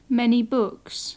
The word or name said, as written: many books